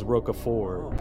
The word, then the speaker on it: Roquefort: Well, the correct answer you actually pronounced wrong is Roquefort.